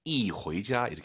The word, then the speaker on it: 一回家: 숫자 1 뒤에 이성이 오면은요, 숫자 1이 사성으로 바뀌어요. 이 호이자 이렇게 발음하시는 거예요. 이게 아니라 이. 꼭 기억하세요.